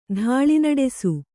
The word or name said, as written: ಧಾಳಿನಡೆಸು